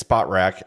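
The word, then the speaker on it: Spotrac: Because I DM'd him once and said, hi, we talk about your website a lot, so how do we say it correctly? And he said, it's SpotRack, and it's terrible.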